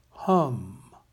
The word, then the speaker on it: ham: The short A in Sanskrit is pronounced like the U in up or hunt, so we say hum, H-U-M, even though we write it as H-A-M.